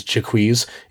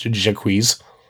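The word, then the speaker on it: Jaques: As somebody who hates Shakespeare, the reason that guy's name is Jacquees is because Shakespeare did not know how to pronounce the French name Jacques. So you can tell from, like, reading, like, how he rhymes and how it fits into the rhythm that he thought it was pronounced Jacquees.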